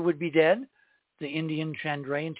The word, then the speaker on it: Chandrayaan: It's Chandrayaan. It's pronounced that way with the emphasis on the last syllable.